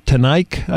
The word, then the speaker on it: Teneyck: And it's by a Laura Teneyck, I think is how you say that name. It's T-E-N-E-Y-C-K. And Dietrich Gehrig, uh-huh. – G-E-H-R-I-N-G.